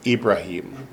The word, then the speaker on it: Ibrahim: How do you say Abraham in Arabic? No, it's Ibrahim. So the vowel is different, the consonants are the same.